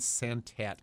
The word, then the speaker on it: Santat: But the author is Dan Santat. And I'm not sure I'm pronouncing his name right. – That is right.